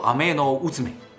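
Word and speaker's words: アメノウズメ: 天羽寿麦命，读作阿麦诺乌兹麦，而灵牙斯兹麦就来自乌兹麦这个名字啊，这是导演自己说的。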